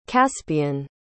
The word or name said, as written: caspian